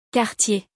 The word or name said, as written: Cartier